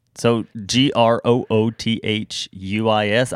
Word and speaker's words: Grotius: I think it's Dutch. – Is it Dutch? Okay. – Grotius. Grotius? – Yeah. – Grotius? – So Grotius.